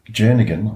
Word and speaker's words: Jernigan: See, that was a great battle of Kenneth Jernigan, I think it's pronounced with a soft G, the former president of the National Federation of the Blind in America, the USA.